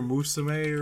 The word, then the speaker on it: Musume: Monster Musume or whatever. I'm butchering that because, you know, oh. I'm a weird... – M-U-S-U-M-E. – Yeah. – It looks like museum, but not museum.